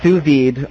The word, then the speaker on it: sous vide: Well, how's that actually pronounced, sous vide rig? – Yeah, sous vide.